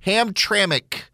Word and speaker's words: Hamtramck: Hamtramck, Michigan. That's the way you pronounce the name.